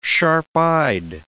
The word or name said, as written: sharp-eyed